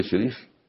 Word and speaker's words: شريف: وراجل شنب كده يقف على الصخر، انا اسمي الشريف، شريف، واللي كسر الشين ايه؟